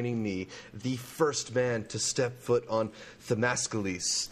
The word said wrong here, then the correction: Themyscira: Did I pronounce it right this time? Themyscira? – You were very close.